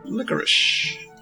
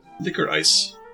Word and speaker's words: licorice: It's licorice. I said it right that time. – Not licorice? – Licorice. Liquor ice. – Hmm. I could use a little bit of both. – Liquor and ice.